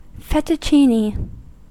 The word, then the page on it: fettuccine: American English